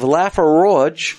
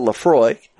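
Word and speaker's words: Laphroaig: In episode 173, we have Laffer Roge instead of Lafroy.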